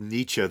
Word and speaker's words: Nietzsche: All right, so this is a follow-up to an episode I did entitled Nietzsche, or Nietzsche, tomato, tomato, as I always say. Maybe I'll go with Nietzsche this time around, since my friend Tim Danaher informs me that is the proper German pronunciation.